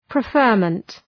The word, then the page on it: preferment: prı’fɜ:rmənt